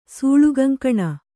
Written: ಸೂಳುಗಂಕಣ

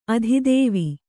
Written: ಆದಿದೇವ

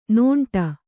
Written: ನೀಂಟು

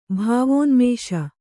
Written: ಭಾವೋನ್ಮೇಷ

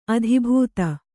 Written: ಅದೆಬದೆ

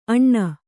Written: ಅಣ್ಣ

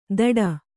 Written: ದಡ

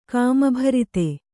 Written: ಕಾಮಭರಿತ

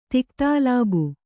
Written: ತಿಕ್ತಾಲಾಬು

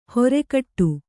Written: ಹೊರೆಕಟ್ಟು

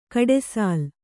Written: ಗುಡಿಸಿಲು

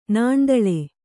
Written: ನಾಣ್ದಳೆ

